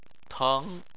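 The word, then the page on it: teng: tone 2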